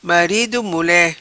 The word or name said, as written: Marido Mule